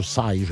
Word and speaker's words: Psy: Oui, mais on prononce ça Psy, je crois. – Ouais. – En tout cas, P-S-Y.